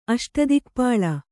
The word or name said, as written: ಅಷ್ಟದಿಕ್ಪಾಳ